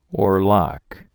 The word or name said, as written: oarlock